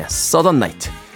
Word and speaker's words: Southern: 이걸 자꾸 일본식으로 사우던이라고 발음을 할 뻔해요. 예, Southern Night 그리고 95년도 역시 같은 차트 5위에 올랐던 디언 페리스의 I Know까지 세 곡의 음악 이어서 듣고 왔습니다.